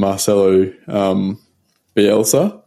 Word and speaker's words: Marcelo Bielsa: So from what I read, I think they approached Sean Dyche and Marcelo Bielsa.